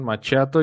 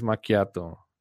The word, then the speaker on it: macchiato: Eh, muchas partes o a veces he escuchado que le dicen machiato, yo también a veces le, he dicho como, como machiato, estuve investigando la pronunciación y me parece que es macchiato, e-, es un espresso macchiato que la traducción es manchado.